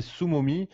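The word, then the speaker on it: SumoMe: Alors le premier c'est Sumomi, ça s'écrit S U MOME.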